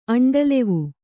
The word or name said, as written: ಅಂಡಲೆವು